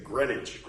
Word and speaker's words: Greenwich: If you go to New York, I'm like, I'm going down to Greenwich Village. People be like, don't ever say that. Say Greenwich.